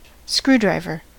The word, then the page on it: screwdriver: American English